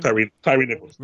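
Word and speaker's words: Tyre Nichols: Tyree Nichols, is that how you say his name? – Tyree Nichols.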